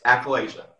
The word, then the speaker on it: achalasia: And correct me if I say it wrong. Achalasia? – Yes. Achalasia.